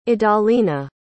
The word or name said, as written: idalina